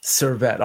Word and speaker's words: Servette: Let's give it a shot. Servetti? Moneyline? -115? – Beautiful. Servette.